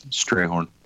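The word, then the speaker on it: Strathairn: And then the actor that was in the last two Godzilla movies was David Strathorn. And he was... – Strayhorn. – That one, too. Damn it! Damn it! – In Jeff's defense, the name, the spelling does not match the pronunciation.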